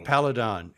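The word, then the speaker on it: Peladon: You know, that reminds me of I believe it was the third Doctor who went to the planet Paladin. I think I'm pronouncing it correctly. – Paladon.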